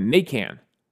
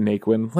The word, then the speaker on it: Naquin: Apparently, it has Cajun roots, like Louisiana roots. And down there, they would call it Nacan. That's how they'd pronounce it, Nacan. And when him and his brother were kids, his dad was like, look, everyone's going to pronounce it Naquin. Let's just go with it.